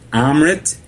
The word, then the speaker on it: Amrit: It's Amrit, A-M-R-I-T, uh-huh. And then his last name is D-E-S-A-I. It's Yogi Amrit Desai.